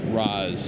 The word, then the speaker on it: Ra's: The rule is R-A apostrophe S is pronounced Roz. It's just how it's pronounced. There's no weird pronunciation of letters in there.